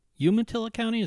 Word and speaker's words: Umatilla: And with me today is Kara Davis, a public defender with a private public defense firm in Umatilla County. Is that how you pronounce it? – Yes.